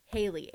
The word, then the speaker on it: Haley: This one is, mine are also like specific, but my name is Haley, H-A-L-E-Y.